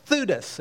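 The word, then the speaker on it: Theudas: Now first he mentions in verse 36 a guy by the name of Thutis. It rhymes with Judas.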